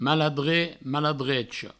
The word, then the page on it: maladrech: maladrecha; mal-adre; mal-adrecho